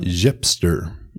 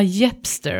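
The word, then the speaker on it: Yepstr: Här finns företag som, den är svår att uttala, kan du uttala den? – Jepster ah, Jepster, okej okay, som hipster ungefär. Jepster, Taskrabbit och Taskrunner.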